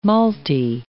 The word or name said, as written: malty